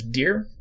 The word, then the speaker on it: Deir: When, I'm going to pronounce it as Deer, D-E-I-R, Deer Taylor catches him, who in this reality is actually Dwayne's father.